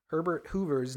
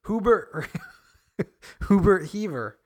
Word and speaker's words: Herbert Hoover: And during a live broadcast in 1931, radio presenter Harry Von Zell accidentally mispronouncing U.S. President Herbert Hoover's name as Hubert Heaver.